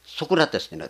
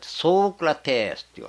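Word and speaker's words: ソ ーク ラ テ ス (Σωκράτης): ソクラテスってソークラテスっていうわけです。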